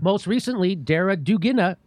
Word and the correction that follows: Daria Dugina: Daria Dugina.